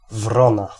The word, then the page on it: corneille: France; /kɔʁ.nɛj/